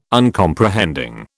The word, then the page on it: Uncomprehending: British English